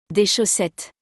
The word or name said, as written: Des chaussettes